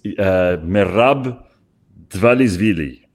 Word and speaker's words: Merab Dvalishvili: Someone wanted me to pronounce the name of someone in the UFC, not Ruben Amorim, it's Merab Dvalishvili.